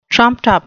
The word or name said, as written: trumped-up